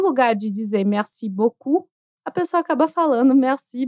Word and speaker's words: beaucoup: O U não tem som de I, como em menu, e o U não tem som de U, como em merci beaucoup.